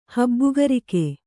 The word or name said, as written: ಹಬ್ಬುಗರಿಕೆ